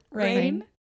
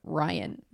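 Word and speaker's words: Rainn: Maybe R-A-I-N. Two How did I not know this? – In my mind, I always say like Ryan, but that's not it. It's definitely spelled like Rain.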